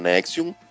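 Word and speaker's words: NXIVM: Não escreve desse jeito, é N-X-I-V-M, mas pronuncia Nexium.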